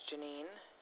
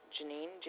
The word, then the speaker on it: Jeanine: My name is Janine Werzer, W-U-R-Z-E-R. Janine, J-E-A-N-I-N-E. – You said J-E-A-N-I-N-E? – Yes.